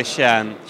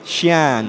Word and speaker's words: Skien: So you were in Norway, and I'll try to pronounce it correctly. Skien? – Well, I'd say Skien. – Skien. – But most Norwegians would say Skien. – It's spelled S-K-I-E... N? – Yes, that's correct, yeah.